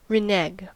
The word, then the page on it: renege: American English